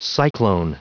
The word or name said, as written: cyclone